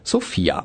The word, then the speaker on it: Sophia: Denn so tritt David Striso in dem Film Transpapa auf, als Sophia. Mit Betonung auf dem I und nicht auf dem O. Also, sonst wäre er ja die Hauptstadt Bulgariens.